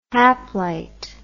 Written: haplite

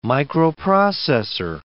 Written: microprocessor

